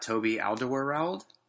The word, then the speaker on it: Alderweireld: We've got Toby Alderweireld. – Yes, great pronunciation.